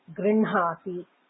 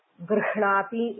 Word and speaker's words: गृह्णाति: गृह्णाति गृह्णाति इति लेखनं किन्तु उच्चारणं गृह्णाति इत्येव सम्प्रदायः तत्र व्याकरणनियमः इति कोऽपि नास्ति किन्तु साम्प्रदायिकाः उच्चारणसमये एवमेव उच्चारयन्ति So it's a unique thing, a special case.